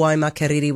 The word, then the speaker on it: Waimakariri: But your Waimakariri was perfect.